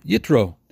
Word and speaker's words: Yitro: This week's parsha is called Yisro, or Yisro, or Yitro, depending on who taught you how to pronounce Hebrew. I'm going to say Yisro.